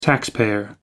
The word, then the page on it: taxpayer: American English